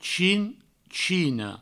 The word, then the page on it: chinchina: chinchino; provençal